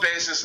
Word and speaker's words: Bayes: Would you spell it? How? What is it again? B-A-S... E? – B-A-Y-E-S. – Oh, okay. – Bayes.